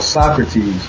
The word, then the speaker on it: Sokratez: But that song is from a project I'm working on right now called Socrates, but it's spelled So Crates, like, you know, JF Crates. So it's like S-O-K-R-A-T-E-Z.